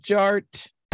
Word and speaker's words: Jart: And my name is Jared, aka DJ Art, with two T's for a double dose of that tink tink. The D is silent, so it's just Jart.